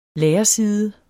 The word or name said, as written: lærerside